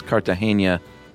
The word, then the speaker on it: Cartagena: My last name is Cartagena, like the city in Romancing the Stone.